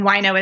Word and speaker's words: Wojno: And then Wino is spelled Wojno, which is so weird.